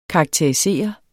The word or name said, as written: karakterisere